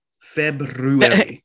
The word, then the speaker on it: February: February. The R is silent.